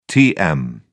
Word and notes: tm: British English

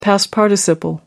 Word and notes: past participle: American English

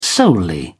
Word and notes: solely: British English

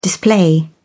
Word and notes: display: British English

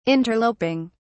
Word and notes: interloping: British English

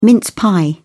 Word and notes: mince pie: British English

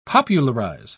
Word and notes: popularise: British English

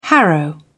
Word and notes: harrow: British English

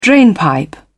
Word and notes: drainpipe: British English

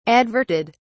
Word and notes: adverted: British English

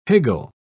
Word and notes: higgle: British English

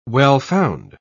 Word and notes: well-found: British English

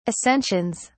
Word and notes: ascensions: British English

